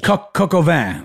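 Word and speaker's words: coq au vin: What is coca vein? – Oh, I'm mispronouncing it. – Coco vin.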